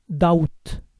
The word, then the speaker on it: doubt: Dudar es doubt. Recuerden no pronunciar la B.